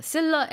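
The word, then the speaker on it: Scylla: 그래서 Silla and Charybdis 이렇게 발음을 했어야 됐네요.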